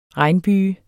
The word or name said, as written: regnbyge